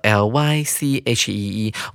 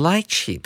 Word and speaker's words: lychee: lychee 它有两种拼法，第一种拼法叫做 l y c h e e，我们会念成 lychee；另外一种拼法是 litchi，l i t c h i，可以念成 litchi 或者是 lychee，两种念法都是可以的。